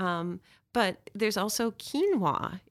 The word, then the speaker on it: quinoa: You don't even know how to pronounce it, um, but it's it's quinoa.